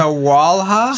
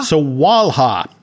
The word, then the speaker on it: Sawalha: How do we pronounce his name? Sawalha. – Sawalha.